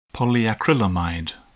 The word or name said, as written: polyacrylamide